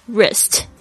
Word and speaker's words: wrist: So write W-R-I-T-E，特别注意一下这个 W 是不发音的，很多 W-R 的这个开头的字呢通常都是 W 不发音，比如说像是手腕 wrist W-R-I-S-T，